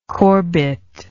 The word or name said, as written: Corbett